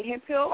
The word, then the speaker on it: Hemphill: But, you know, for more information about me, contact me on Google, you know what I'm saying, keyword, Blaine Hempfield, or you can also type in Blaine the Model, and you spell that B-L-A-Y-N-E, and my last name is H-E-M-P-H-I-L-L.